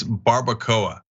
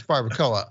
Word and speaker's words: barbacoa: It's barbacoa. – Oh yeah, no, I, that's right. It's barbacoa.